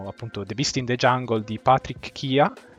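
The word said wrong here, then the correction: Chiha: credo che si pronunci, Shiha, credo. – Shiha, ok. Che è stato presentato alla 73esima mostra berlinale, berlinale, sì.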